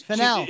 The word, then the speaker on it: Fennell: I mean, Emerald Vanell, is that how you say her name? Emerald Vanell. She's Fennell. – Fennell. It's Fennell, a fascinating.